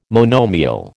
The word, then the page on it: monomial: American English